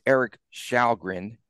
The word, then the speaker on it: Källgren: I have to make sure that I make a correction too in the goaltender's pronunciation. It's Erik Källgren. Okay. Cause he's Swedish and they pronounce their names differently, but he faced just 20 shots.